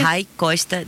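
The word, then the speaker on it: Ray Costa: Para que ustedes sepan cómo se escribe, se los voy a deletrear. R-A-Y Costa. Me estaban contando que igual que tu nombre se escribía diferente, pero lo estamos pronunciando tal cual lo tendríamos que pronunciar.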